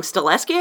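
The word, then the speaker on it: Stahelski: Stileski, I think that's right.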